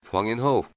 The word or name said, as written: Hwang In-ho